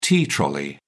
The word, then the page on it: tea trolley: British English